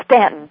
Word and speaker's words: Staunton: Well, it's really called Staunton, dear. – Staunton. – The U is silent.